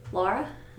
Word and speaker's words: Lara: How do you say it? – Laura. – Laura? – Yeah, L-A-R-A. – Like Lara Croft. – Sure. Yes. – So you're saying it more like Laura with a U.